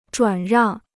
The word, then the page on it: 转让: zhuǎn ràng